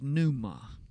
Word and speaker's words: pneuma: The word that's translated Spirit is pneuma. That's got a silent P in it. P-N-E-U-M-A.